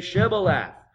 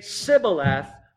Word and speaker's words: Shibboleth: If he said no, then they would say to him, then say Shibboleth. And he would say Sibboleth, for he could not pronounce it right. That's an unfortunate way to meet your end. The difference between a sh and a s.